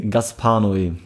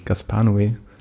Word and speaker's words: Gaspar Noé: Gaspar Noé. – Gaspar Noé.